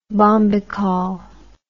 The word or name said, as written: bombykol